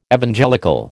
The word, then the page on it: Evangelical: American English